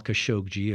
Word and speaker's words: Khashoggi: I understand that is the correct pronunciation, hard G, soft G. Jamal Khashoggi,